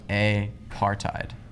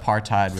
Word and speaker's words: apartheid: The other day, I realized that like apartheid was like one word, when I always thought it was apartheid, and I thought that apartheid was something, but like it's apartheid. It's one word.